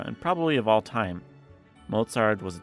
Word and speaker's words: Mozart: Two syllables, it's a noun or a name. Mozart. Remember to make that tss sound. Mozart.